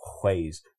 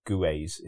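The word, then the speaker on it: Gueuze: Whewell, not Guez, if you're English, right? Whewell. I had a barman shout it in my face when I said, "Oh, I'll have a Guez Boone." It's Whewell, like that.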